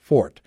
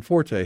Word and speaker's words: forte: The noun sense of the word was originally pronounced as a single syllable, fort, as in French. However, the two-syllable pronunciation, forte, has mostly supplanted it.